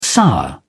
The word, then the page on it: sour: British English